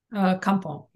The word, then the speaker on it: Kampong: Kampong, am I saying that correctly? – Kampong, yeah.